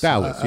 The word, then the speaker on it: Dalys: It looks like Dallas to me. – Yeah, D-A-L-Y-S. – Dallas, yeah. – Is that how you pronounce that, Steve? – I'd go with it.